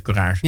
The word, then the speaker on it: Courage: Hotel, uh, Theater Hotel Courage. Spreek je het op zijn Frans uit of op zijn Ja, op Frans. – Theater Hotel Courage.